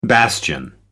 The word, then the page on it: bastion: American English